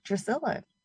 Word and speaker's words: Drusilla: Growing up, it was always like my name was like mispronounced. Like it's like Leticia, Leticia, or Letty. It's just kind of like whatever, you know, like people come up with. but my actual name is Drusilla.